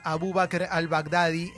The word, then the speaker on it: Abu Bakr al-Baghdadi: durante el fin de semana mataron a Abu Bakr al-Baghdadi, líder del, Estado Islámico.